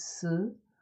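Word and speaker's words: si (四): in Chinese, how they pronounce it, it's si, si, like si,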